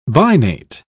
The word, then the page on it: binate: British English